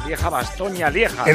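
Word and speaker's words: Lieja-Bastoña-Lieja: Lo he dicho bien, Pedro. Lieja Bastoña Lieja. – Enhorabuena, enhorabuena, sí.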